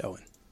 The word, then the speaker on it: Eoin: He is Irish, and it's spelt E-O-I-N. Pronounced Owen.